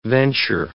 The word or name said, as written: venture